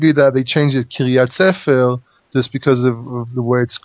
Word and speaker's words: Kiryat Sefer: But basically, he said, whoever attacks, is it Kiryat Sefer? Is that how I pronounce it? – Kiryat Sefer, the town of the book.